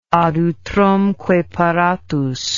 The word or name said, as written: ad utrumque paratus